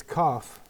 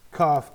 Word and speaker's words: Kaf: It's Kaf. – Yes. – Pronounced like cough. Kaf.